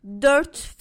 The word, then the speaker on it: Dört: Dört fincan şeker. Dört fincan şeker. – That O with the two dots can be sort of tricky for us English speakers to get a hang of. – Dört.